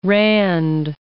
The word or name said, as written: rand